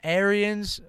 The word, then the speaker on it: Arians: Arians, I know I say his name wrong every time.